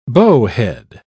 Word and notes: bowhead: British English

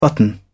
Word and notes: button: British English

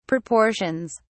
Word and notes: proportions: British English

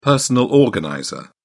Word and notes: personal organizer: British English

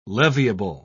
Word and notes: leviable: British English

